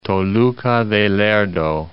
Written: Toluca de Lerdo

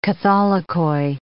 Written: catholicoi

